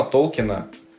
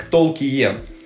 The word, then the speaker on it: Толкин: А фамилия профессора произносится, кстати, исключительно как Толкин, потому что есть некоторые книги, о переводах мы еще поговорим, где его пишут как Толкиен. Это полная хуйня.